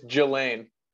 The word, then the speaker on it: Ghislaine: It's Ghislaine. – Ghislaine? Isn't it spelled Ghislaine. Like Ghislaine or something? I don't know. – Yeah, it's Ghislaine. – Yeah. Anyway, Ghislaine Maxwell.